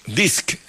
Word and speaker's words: Disc: Disc. – Dix. En Paraguay. – No, espera. Disc.